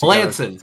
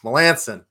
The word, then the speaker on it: Melancon: Mark Melancon is going to be – I feel like I said that wrong. Melancon. – Tell me. Melancon.